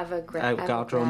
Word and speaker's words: Avogadro: That means from that time, this is the Lodmich number, or how do you say here, Avogadro number? – Yeah, Avogadro number.